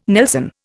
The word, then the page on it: nelson: Indian